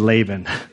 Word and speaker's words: Laban: And I think there is a huge lesson in the story of Jabin and Laka, Jacob, Jacob and Laban, of like, that there, did I say it wrong again? Jacob and Laban.